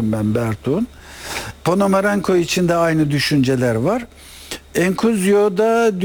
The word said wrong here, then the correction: Enciso: dünya... – Enciso. – Özür dilerim telaffuz için.